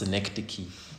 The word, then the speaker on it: synecdoche: Okay, it's pronounced synecdoche. There we go.